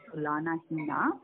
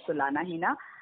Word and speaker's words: Tulanahina: So, like in Hindi, you know, Tulna means comparison, and Hina actually means no. So, no comparison. So, no Tulna. So, that's how my name is composed, and I mean, that's what is the meaning of my name. So it's pronounced as Tulanahina.